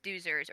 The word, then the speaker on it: Doozers: Because they're pronounced doozers, or is it dozers? – Doozers.